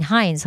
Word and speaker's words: Hines: And we did the program with him, Frank and I, him and Kevin Hines, huh? That's how he pronounced his name.